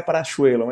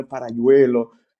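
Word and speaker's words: Paralluelo: Na Argentina seria Parachuelo, mas é espanhola, né? Parayuelo.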